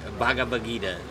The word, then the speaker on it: Bhagavad Gita: We just had a Do you know how to pronounce it? Bhagavad Gita? – Bhagavad Gita. – Okay. – That is way more beautiful than what I said.